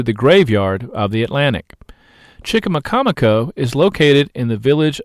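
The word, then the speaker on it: Chicamacomico: Chickamaucomico, pronounced Chickamaucomico, is a 400-year-old Indian name for a famous place on Hatteras Island.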